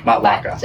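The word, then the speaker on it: Matlacha: And Michelli's down in Matt Lachey. – Yep. – Matt Lachey. It we couldn't pronounce it when we first moved here. – Matlaka. – Matlaka.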